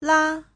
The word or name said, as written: la5